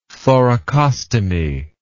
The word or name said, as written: thoracostomy